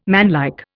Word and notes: manlike: Indian

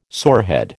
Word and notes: sorehead: American English

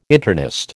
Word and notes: internist: American English

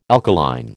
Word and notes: alkaline: American English

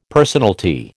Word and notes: personalty: American English

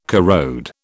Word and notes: corrode: British English

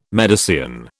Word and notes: Medicean: British English

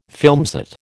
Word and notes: filmset: American English

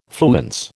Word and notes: fluence: American English

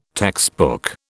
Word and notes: textbook: British English

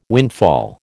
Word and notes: windfall: American English

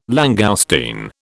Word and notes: langoustine: British English

